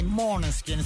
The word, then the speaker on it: Måneskin: Ma siamo orgogliosi dei Måneskin, sappiamo, dei Måneskin, scusate, pronuncia così.